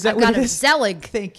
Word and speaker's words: Zelig: We can talk about Zelig. Z- am I pronouncing Zelig. right? Zelig.